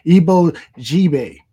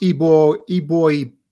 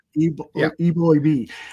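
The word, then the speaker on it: Eboigbe: Justin, and this one I'm going to have trouble with, Ibo Jibe. – It's actually pronounced Ibo B. – Okay. So the G is silent. – Yeah. – Eboy B.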